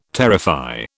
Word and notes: terrify: British English

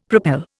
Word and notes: propel: Indian

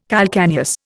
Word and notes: calcaneus: Indian

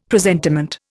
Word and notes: presentiment: Indian

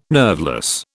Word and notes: nerveless: British English

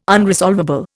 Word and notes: unresolvable: Indian

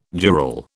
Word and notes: jural: British English

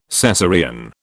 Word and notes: Cesarean: British English

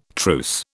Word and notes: truce: British English

